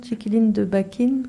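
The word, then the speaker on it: Chiquilín de Bachín: On met par exemple Chiquiline de Bakin de Piazzolla. – Exactement. – Je sais pas si je prononce bien. – C'était parfait. Thank you.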